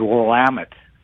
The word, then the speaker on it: Willamette: I was calling because first thing, proper pronunciation is Willamette for the winery region up in Oregon.